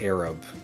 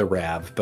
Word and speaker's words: Arab: Also, Chelsea, just so you are aware, the word is pronounced Arab, not Arab, but I don't want you to go back and fix that, because that's great.